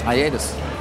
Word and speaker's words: hiatus: Hiatus. – You pronounce the H.